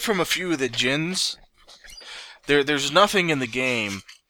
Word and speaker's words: djinns: Yeah, I think that's how you pronounce it. I think the D is silent. – Yeah, djinns.